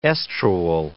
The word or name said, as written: estrual